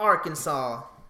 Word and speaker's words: Arkansas: Arkansas or Arkansas goes to Mr. Donald J. Trump. Did I say that right? Yeah.